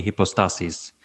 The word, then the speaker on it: hypostases: Ousia is singular, and hypostasis would be singular also, and then in the plural, it would be hypostases. So when you make the word plural, you have to move the accent over to the second to last syllable.